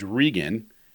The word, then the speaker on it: Regan: And as soon as Ronald Reagan became president, no one could pronounce our last name. It was always Reagan. – Oh, right? – Yeah. – But you spell it R-E-G-A-N, right? – No, just R-E-G-A-N. So Reagan is R-E-A.